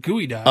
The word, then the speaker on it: geoduck: Is that just spelled like geoduck? It's a gooey duck? I've heard of gooey ducks. – Oh, Purple says it's pronounced gooey duck.